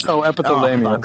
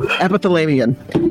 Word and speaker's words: Epithalamion: But Epithalamion. – Oh, see? thank you. Epithalamion.